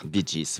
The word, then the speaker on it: Bee Gees: Bee Gees, voilà, c'est ça. J'avais pas la bonne prononciation tout à l'heure.